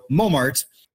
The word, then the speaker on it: Montmartre: So the T was lost just because it was never pronounced to begin with, but that on became nasal, so we ended up with mo, right? With the maître, that R-E at the end is hard to pronounce. We don't have those type of final clusters in English. So what we did with it is dropped it off. So we ended up with Mart. So Montmartre.